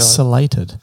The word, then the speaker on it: ciliated: And examples as the slated – is that how you pronounce it? Ciliated, not ciliated.